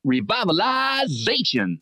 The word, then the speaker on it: revitalization: It's our heating system revivalization. – It's more like revitalization.